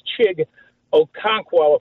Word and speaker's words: Chig Okonkwo: I really like this Chig Okonkwo, if I'm pronouncing it right. And he's playing Jacksonville or Kraft versus Minnesota. – Yeah, you know, I'd say don't fall in love with Chig Okonkwo. I do think you nailed the pronunciation there.